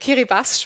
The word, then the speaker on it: Kiribati: Kiribati spricht man es aus in der okay, Landessprache.